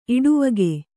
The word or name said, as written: ಇಡುವಗೆ